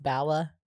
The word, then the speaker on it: Bala: I do have ankle / wrist weights from, I think the company's pronounced Bala, B-A-L-A.